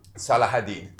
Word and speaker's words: Salah ad-Din: I think it's Salah. – Look up Salah ad-Din. That's the way that you pronounce it and all that stuff.